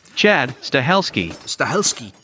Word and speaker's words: Chad Stahelski: Chad Stahelski.